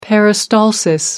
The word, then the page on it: peristalsis: American English